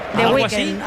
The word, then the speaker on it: The Weeknd: Yo creo que es The Weeknd. – The Weeknd.